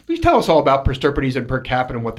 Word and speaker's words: per stirpes: And that is, am I saying it right? Per stirpes. Yeah, you said it Finally, right. like the 15th yeah, yeah.